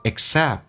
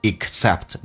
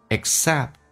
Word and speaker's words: except: all except，首先我们讲一个单词叫 except 那个单词，哎，轻轻的，我们来发现那个 k 是不用发音的，叫 except，不要发成 except， no， except。